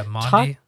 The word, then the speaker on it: Maundy: Yeah, Maundy.